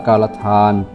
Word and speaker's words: กาลทาน: สะกดอ่านว่ากาลทาน